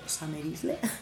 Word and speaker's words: Summer Isle: Escriben ahora su nombre solo con mayúsculas, pero se sigue pronunciando Summer Isle.